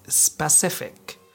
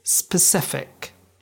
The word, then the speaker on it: specific: Many people mispronounce it as specific, but the correct pronunciation is specific.